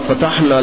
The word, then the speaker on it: فتح: جس کو ہمارے یہاں اردو میں جب بولتے ہیں تو فتح تعبیر کرتے ہیں، اصل تلفظ فتح ہے.